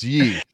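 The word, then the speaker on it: Ye: So, Kanye, we're calling him Yee because who knows how it's pronounced. – Yay. Wait, Yee. It's Yee. That's right.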